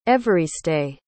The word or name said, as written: evariste